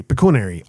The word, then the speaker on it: pecuniary: Pecuniary.